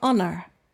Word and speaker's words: honor: H is silent for honor. And that's the end of this lesson.